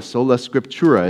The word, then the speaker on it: sola scriptura: The Bible, the sola, sola scriptura is how you say this.